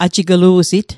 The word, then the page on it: ajiglu'sit: ajiklu'sit; Mi'gmaq